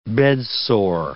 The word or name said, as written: bedsore